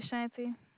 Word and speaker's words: Ashanti: My name is Ashanti. – How do you spell it? – A-S-H-A-N-T-I. – A-S-H? – A-N-T-I. – Ashanti, A-S-A-N-T-I? – Yes.